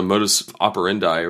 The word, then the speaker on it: modus operandi: That's been the modus operandi, or how do you say it? – Modus operandi.